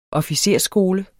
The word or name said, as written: officersskole